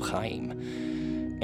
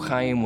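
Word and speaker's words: Khaem: Chaim. And Chaim is spelled K-H-A-E-M. I just like doing the ch sound.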